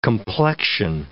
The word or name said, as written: complexion